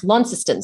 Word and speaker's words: Launceston: It's pronounced Launceston. So it's actually spelt the same way, and no doubt it was named after that town.